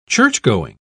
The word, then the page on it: churchgoing: British English